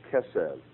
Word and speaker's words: chesed: Let's take a beautiful Hebrew word like chesed, which is spelled C-H-E-S-E-D.